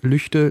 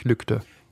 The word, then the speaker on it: Lügde: Weil die Menschen in Lüchte Lüchte sagen und nicht Lügde.